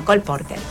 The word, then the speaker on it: Cole Porter: ¿Cómo se dice? – Cole Porter.